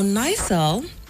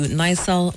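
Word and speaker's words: NIRSAL: Now, Nysel is actually the one that's in charge of the Axmis Microfinance.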